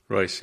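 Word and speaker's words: Reuss: He really is in charge of the whole EV operation at GM under Mark Roos. How do you pronounce his last name? – Royce, yeah.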